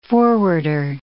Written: forwarder

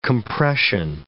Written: compression

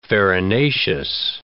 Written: farinaceous

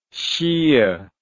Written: shiah

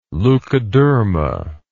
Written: leukoderma

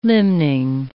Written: limning

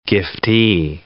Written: giftee

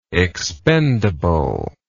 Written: expendably

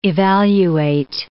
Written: evaluate

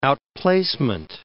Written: outplacement

